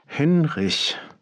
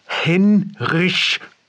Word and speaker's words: Henrich: Henrich. – Hendrich? – Henrich. Oh, wie Heinrich ohne I, Hen wie Henne ohne Ne und Rich wie Richard ohne AD oder wie Reich auf Englisch, nur deutsch ausgesprochen. Henrich.